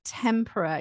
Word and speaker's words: tempera: And I can't finish this conversation, without asking you a little bit about egg tempera. Is that the correct way to pronounce it? – It is, rather than tempera, which is a batter that you dip your fish in.